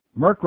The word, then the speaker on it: Merck: Merck rhymes with jerk.